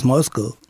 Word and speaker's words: Moscow: How do you properly pronounce Moscow? I hear Moscow, I hear Moscow. – In Russia, it's Moskva. – Moskva. – And in English, it's Moscow.